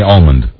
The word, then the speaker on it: almond: Yeah, I thought you said Almond when you first said it, but Almond, pronounce the L? – I say yes. I say Almond.